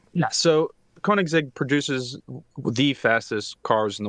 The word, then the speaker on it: Koenigsegg: KOW-NEEGS-EGG. – Yep. – KOW-NEEGS-EGG. – Nope. – Zeg? – There's a G in the middle. KOW-NEEGS-EGG. – KOW-NEEGS-EGG.